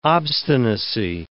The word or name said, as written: obstinacy